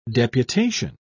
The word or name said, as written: deputation